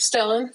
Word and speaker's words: Stellan: Is it Stellan? That's how you say his name, right? – Stellan.